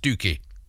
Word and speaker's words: Stiffkey: I love Stiffkey, pronounced Stookie.